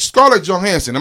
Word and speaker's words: Scarlett Johansson: Scarlett Johansson. Am I saying her name right? – Yes, you are.